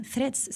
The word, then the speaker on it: Threads: Es que no lo digo bien, threads se dice.